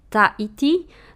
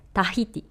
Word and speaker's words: Tahiti: Podobnie jak kiedy mówimy taiti, to mówimy po francusku, bo Francuzi nie wymawiają H, a prawdziwa nazwa jest Tahiti albo Tahiti,